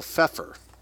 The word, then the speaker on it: Pfeffer: a law professor by the name of Leo Pfeffer, silent P, let's say, a German Jew type name, we've heard him before.